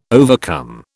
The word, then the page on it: overcome: British English